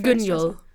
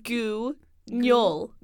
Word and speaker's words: Guignol: Yes, every time I say it, it's Guignol. The Grand Guignol. It's spelled. – Just don't look at the U. You can. – Guignol? – It's like Guignol.